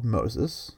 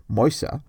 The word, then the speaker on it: Moses: Nevertheless, Pharaoh insists, and things get dire enough that when a boy called Moses, or if you'd like more accurate pronunciation, something like Moisa, is born.